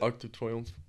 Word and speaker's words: Arc de Triomphe: Arc de Triomphe? – The Arc de Triomphe.